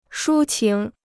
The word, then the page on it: 抒情: shū qíng